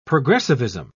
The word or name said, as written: progressivism